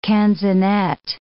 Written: canzonet

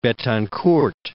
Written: Betancourt

